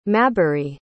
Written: mabry

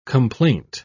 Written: complaint

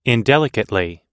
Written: indelicately

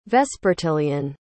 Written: vespertilian

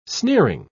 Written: sneering

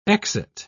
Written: exit

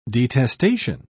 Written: detestation

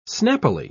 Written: snappily

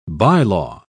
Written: bylaw

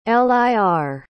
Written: lir

